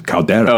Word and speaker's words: caldera: And you hammered me for pronouncing it wrong, but I'm pronouncing it correctly now. It's caldera.